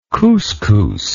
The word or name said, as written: couscous